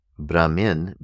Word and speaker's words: Brahmin: The scholars and clerics are referred to as Brahmins, not to be confused with the word Brahman. Brahman, B-R-A-H-M-A-N, Brahman, is a completely different word, and it means totality consciousness. Brahmin, B-R-A-H-M-I-N, is the name of a caste of scholars and clerics.